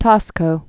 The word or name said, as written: Taxco